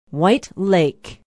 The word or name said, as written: white lake